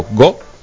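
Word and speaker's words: go (五): Eh, para empezar, bueno, el número 5 se, se pronuncia como go y el número 9 Q.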